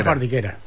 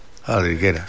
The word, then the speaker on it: faltriquera: Eso son las faltriqueras. – Faldriquera. – Que nosotros decimos faldriquera, pero se escribe, me parece que no es...